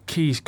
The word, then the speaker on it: Caius: But I know it's Keys College at Cambridge, but surely the fact it's Marcius, it sounds Roman. I know a lad called Caius as well, and that's how you say it. He's not called Keys.